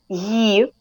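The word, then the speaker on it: Ye (Є): How do you say that, that letter? – Ye. – Okay, so it's kind of like try ye. To put Y and I, but pronounce it as the one sound.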